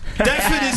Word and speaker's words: Deptford: Deptford is a fucking annoying word because it's got a silent P in it, and I only figured that out like a week ago.